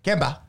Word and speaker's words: Kemba: Kemba.